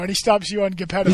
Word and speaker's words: Geppetto: I thought it's a soft G. It's not Geppetto. – Yeah, it's Geppetto.